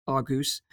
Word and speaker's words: Argos: Now, this is where Argos comes in. If you're not familiar with Argos, it's like a outlet here in the UK. If you're really fancy, it's pronounced Argus.